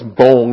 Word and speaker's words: Bong: But anyway, and the Filipino pronunciation is Bong anyways. It's a long O.